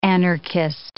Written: anarchist

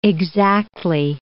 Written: exactly

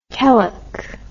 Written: Kelleck

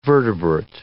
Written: vertebrate

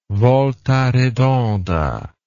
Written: volta redonda

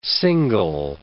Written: single

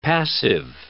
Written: passive

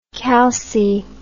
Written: calci

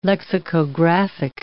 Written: lexicographic

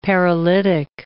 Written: paralytic

